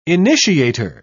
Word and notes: initiator: British English

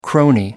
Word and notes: crony: American English

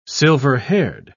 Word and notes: silver-haired: British English

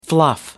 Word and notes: fluff: American English

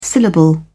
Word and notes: syllable: American English

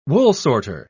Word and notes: woolsorter: British English

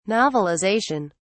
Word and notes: novelization: British English

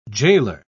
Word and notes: jailor: British English

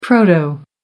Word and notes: proto: American English

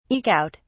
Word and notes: eke out: British English

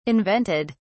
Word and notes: invented: British English

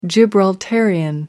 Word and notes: Gibraltarian: American English

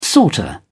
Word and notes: sorter: British English

